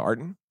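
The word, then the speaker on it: Ayrton: Arton. Yeah. – Well, that would have been a good name too, the Arton.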